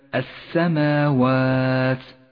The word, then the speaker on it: السماوات: المثال التالي اللام الشمسية في كلمة السماوات، لاحظ كيف لونت اللام بالرمادي إشارة إلى عدم نطقها، نعم تكتب ولا تلفظ.